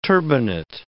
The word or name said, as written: turbinate